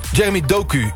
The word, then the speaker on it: Jeremy Doku: Over, spreek je zijn naam uit als Jeremy Doku? – Jeremy Doku, ja.